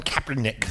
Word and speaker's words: Kaepernick: Colin Kaepernick.